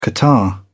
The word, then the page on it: catarrh: British English